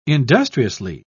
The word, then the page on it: industriously: British English